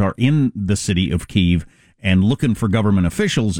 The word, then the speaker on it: Kyiv: Because Ukrainians pronounce it Kyiv. Russians pronounce it Kiev.